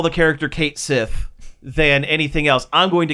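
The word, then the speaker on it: Cait Sith: And I don't care that his name is Cat She. – Really? That's how they say it's pronounced? – That is how... Well, it is Cat She. – In Irish folklore, it's Cat She.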